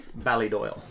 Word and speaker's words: Ballydoyle: Ballydoyle. – Ballydoyle. – Ballydoyle Racing is the racing arm of the Coolmore Thoroughbred Empire, so to speak. And I don't do work for them, for Ballydoyle itself anymore.